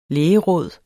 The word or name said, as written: lægeråd